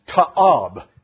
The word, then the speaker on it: ta'ab: And the word in the Hebrew for abominable is pronounced ta'ab. Can you say it with me?